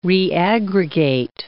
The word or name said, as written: reaggregate